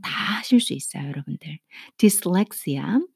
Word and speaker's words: dyslexia: 난독증은 디슬렉시아, 어, 디스렉시아라고 읽어요. 아, 난독증. 조금 단어가 좀 이상해 보이죠. 뭔가가 디스 하면 dis 같은데 dys로 시작을 해요.